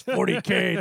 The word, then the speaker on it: 40K: Um, do, will be doing so on Avengers Infinity and Warhammer 40,000 by shifting or 40k. Someone's going to tell me I said that wrong. 40K. You old man. – It's 40K, not 40,000.